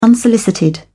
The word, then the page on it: unsolicited: British English